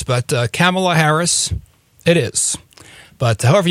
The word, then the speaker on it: Kamala: But apparently it's Kamala Harris, not Kamala Harris. – Yeah, it's interesting because K-A-M-A-L is it does have different pronunciations.